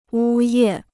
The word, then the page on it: 呜咽: wū yè